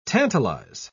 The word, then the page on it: tantalise: British English